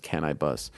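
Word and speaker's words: Canibus: I was a big Cannabis fan back in the day. Or is it Can I Bust? I think it's actually pronounced Can I Bust.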